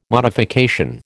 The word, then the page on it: modification: American English